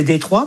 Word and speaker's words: Détroit: Ouais, alors déjà, c'est une ville que ça devrait se prononcer Détroit, parce que c'est une ville française, en fait, oui.